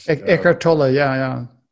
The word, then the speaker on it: Eckhart Tolle: He reads Eckhart Tolle now, and if I'm pronouncing his name right, Eckhart Tolle, power of now.